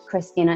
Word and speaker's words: Cristina: Just type in my name, Cristina Morales, Atlanta, Georgia. Cristina is spelled without an H, my friends. C-R-I-S-T-I-N-A. But yeah, feel free to read up on my profile there if you're looking for couples counseling, and that's how you can find me.